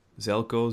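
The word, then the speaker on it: Željko: Probably going to butcher the name here, but I believe it's pronounced Zelko. Zelko, Z-E-L-J-K-O, maybe an Eastern European name.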